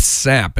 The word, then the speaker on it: Psapp: We finished up there with a song from a band named Sap, and it's spelled P-S-A-P-P.